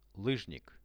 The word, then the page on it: lyzhnik: Russian